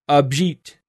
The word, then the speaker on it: Abhijit: He has given me the pronunciation of his first name, and it is actually Abhijit. I think I said that correctly that second time.